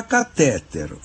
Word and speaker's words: catéter: e é ótimo que ele tenha acolhido a pronúncia dominante, que é catéter. O fato importante é, se você prefere catéter, escreva catéter, ou seja, coloque acento no E que vem depois do primeiro T.